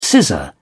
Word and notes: scissor: British English

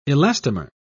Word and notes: elastomer: British English

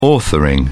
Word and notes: authoring: British English

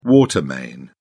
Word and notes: water main: British English

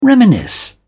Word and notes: reminisce: British English

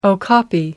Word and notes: okapi: American English

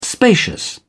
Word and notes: spacious: British English

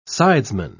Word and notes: sidesman: British English

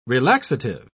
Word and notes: relaxative: British English

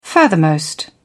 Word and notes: furthermost: British English

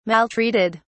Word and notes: maltreated: British English

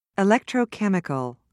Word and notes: electrochemical: British English

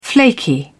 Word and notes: flaky: British English

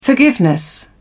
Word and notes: forgiveness: British English

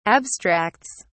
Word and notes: abstracts: British English

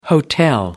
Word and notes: hotel: American English

